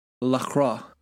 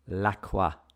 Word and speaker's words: LaCroix: Well, and it should be noted, listeners, that this Scotch is being chased down by what I've now recently learned is pronounced Lacroix. – Lacroix.